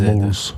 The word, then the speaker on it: Malz: M-A-L-Z. – M-A-L-Z. Moles.